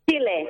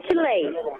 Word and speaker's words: Chile: Is it Chile or Chile? – Chile. – Chile? – Chile. – Chile, not Chile.